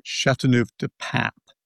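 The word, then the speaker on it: Châteauneuf-du-Pape: And let me correct it by saying Châteauneuf du Pape, not Châteauneuf du Pape. So Châteauneuf du Pape. – Du Pape. There you go.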